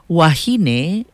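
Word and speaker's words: wāhine: Wahine is singular for a woman. Wahine, with the kahakou or the makron over the A, pronounced wahine, is plural for women.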